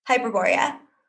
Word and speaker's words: Hyperborea: One of many, the one about, oh gosh, I always pronounce it wrong. So that's where you corrected for me. Hyperborea? – Hyperborea. – Hyperborea, thank you.